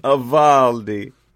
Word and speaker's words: Avaldi: How do you say that one? – Avaldi. Not like it's a spicy meatball.